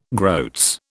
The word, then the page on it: groats: British English